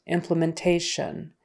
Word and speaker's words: implementation: And then for implementation, the syllable stress is on the next to the last syllable.